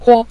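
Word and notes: huo: tone 5

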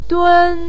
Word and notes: dun1: pinyin

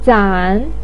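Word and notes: zan: tone 3

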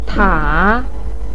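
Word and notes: ta: tone 3

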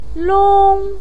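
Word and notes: long1: pinyin; tone 1

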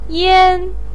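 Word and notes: yan: tone 1

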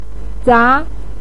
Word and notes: za: 2

